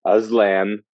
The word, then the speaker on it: Aslan: Sorry, Aslan.